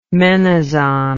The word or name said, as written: menazon